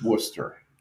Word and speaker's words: Worcester: It's not like people, actively go to, Worcester because they're like, and I use that Worcester because I want to make sure I'm getting the Boston pronunciation. – No, you're actually saying it wrong. It's Worcester. – Worcester.